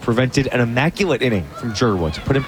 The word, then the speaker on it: Dzierwa: Joseph Jerwa, whose last name is D-Z-I-E-R-W-A, pronounced Jerwa, he deals.